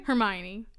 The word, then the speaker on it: Hermione: Did I say her name right? – Hermione.